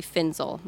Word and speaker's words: Finzel: And you can put my name, Julie Finzel. My last name is spelled F as in Frank, I-N-Z-E-L.